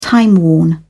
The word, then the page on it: timeworn: British English